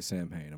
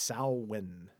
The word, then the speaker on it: Samhain: I always say Samhain. Am I wrong? – Samhain. – Apparently, yeah. I'm wrong. – No, it's pronounced Samhain.